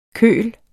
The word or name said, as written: køl